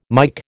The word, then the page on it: Mike: American English